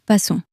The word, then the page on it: passons: french